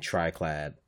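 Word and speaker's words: Triclyde: So, Birdo, Mouser, Fry Guy, and Triclide, Clyde, and that's how you say his name, are all bosses in the game.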